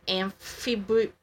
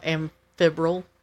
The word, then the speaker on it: amphibole: And it's actually you probably know how to say this, amphibole, A-M-P-H-I-B-O-L-E. – Oh, amphibrole. – But there's no R. – I'm not sure. – Like, it's almost like amphibian.